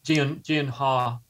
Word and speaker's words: Gyllenhaal: Pronounced Gyllenhaal. Gyllenhaal, actually.